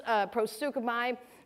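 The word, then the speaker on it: prosukumai (προσεύχομαι): And so that is prosukumai.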